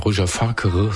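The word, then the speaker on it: Roger Fakhr: Wenn ich das richtig ausspreche, Roger Fakir, so wird's ausgesprochen, geschrieben.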